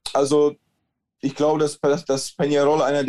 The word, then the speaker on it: Peñarol: Dein Club Atletico Peñarol, ich hoffe, ich habe das richtig ausgesprochen mit diesem Enje. Ähm, Peñarol. – Peñarol, genau.